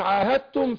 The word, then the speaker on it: عاهدتم: لا، هنا إدغام متجانسين، أنا أدغمت الدال بتاعت عاهدتم في التاء، فبقول عاهدتم، يعني مش بنطق الدال، يا أسرع عاهدتم، بس أنا ما بنطقش الدال، فبندغم الدال في التاء إدغام متجانسين، فبقول إلى الذين عاهدتم، عاهدتم، بنطق بعد الهاء التاء، والدال أدغمت فين؟